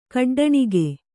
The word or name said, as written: ಖಡ್ಡಣಿಗೆ